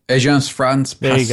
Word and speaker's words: Agence France Presse: Agence France Presse.